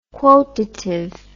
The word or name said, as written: quotative